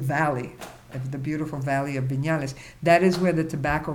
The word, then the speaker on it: Viñales: which means Viñales Valley. Viñales is spelled V as in victory, I, N, that's the N with the little mm-hmm. dito on top of it, A-L-E-S, Valley of Viñales.